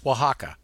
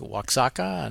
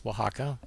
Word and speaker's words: Oaxaca: Well, it's pronounced Oaxaca, but, you know, some people that I've spoken with in Mexico might say something like Oaxaca, and I said, well, no, it's Oaxaca.